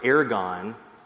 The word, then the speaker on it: ergon: And the last part is pronounced ergon. And I think that might be in the Greek or Latin.